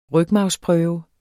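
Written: rygmarvsprøve

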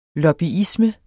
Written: lobbyisme

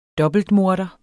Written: dobbeltmorder

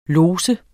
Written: lods

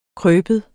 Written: krybe

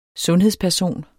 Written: sundhedsperson

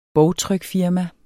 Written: bogtrykfirma

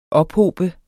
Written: ophobe

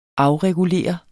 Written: afregulere

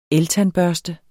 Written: eltandbørste

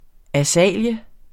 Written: AZALEA

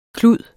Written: klud

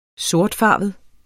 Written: sortfarvet

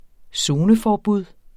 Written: zoneforbud

